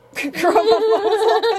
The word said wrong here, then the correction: chromosomal: Chromomosal, am I saying that right? Chromomosal? Chromosomal. Anyway. Chromosomal.